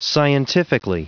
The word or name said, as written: scientifically